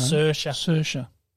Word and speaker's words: Saoirse: I can never work out how to pronounce her name. – Saoirse. Saoirse Ronan.